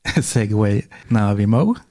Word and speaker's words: Segway Navimo: Kan du uttala det en gång till? – Segway Navimo. – Okej, ja. – Ja. Mo som är Mo alltså, och sen Navi.